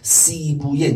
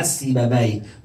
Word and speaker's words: سیبویه: حالا به غلط ما میگیم بابه بی، اشکال هم نداره، وقتی بابویه بیاره تو زبان عربی وی تلفظ میشه. سیبویه نه سیبه بی، مسکویه نه مسکوی، شیرویه نه شیروی.